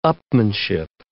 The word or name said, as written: upmanship